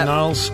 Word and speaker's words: Gnarls: It is a silent G on the start of his name, isn't it? – Gnarls Barkley, yeah. – Gnarls.